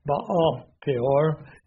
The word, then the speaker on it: Baal Peor: They joined themselves unto Baal Peor, is the way we would say it. Baal Peor would be more accurate pronunciation, and ate the sacrifice of the dead.